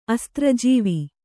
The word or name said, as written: ಅಸ್ತ್ರಜೀವ